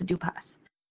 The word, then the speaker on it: Dupas: I didn't want to say the last name wrong, so I had to look up how to say it, but it's Dupas.